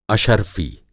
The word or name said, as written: अशरफ़ी